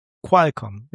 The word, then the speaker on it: Qualcomm: Und Qualcomm wird mit KW ausgesprochen anscheinend. – Qualcomm, ja. – Also so eher wie doch, Qual. Da, Qual. – Ja, so haben wir es doch gehört. – Wie die Qual der Wahl.